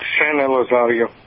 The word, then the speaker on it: San Elizario: Is that how you pronounce that town in Texas? What's the name of the town? – It's San Elizario.